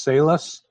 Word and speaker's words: Salus: Is it pronounced Salus? S-A-L-U-S? – Correct.